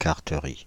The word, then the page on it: carteries: France (Île-de-France); /kaʁ.tə.ʁi/